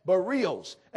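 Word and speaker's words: bareos: It's B-A-R-E-O-S, Barrios.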